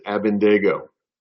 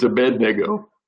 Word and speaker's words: Abednego: whose name I was pronouncing incorrectly, and my wife has corrected me, and his name is Abednego. She just looked at me. It's Abednego. Anyway, Shadrach, Meshach, and Abednego.